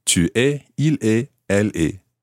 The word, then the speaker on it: tu es: Tu es. – Tu es.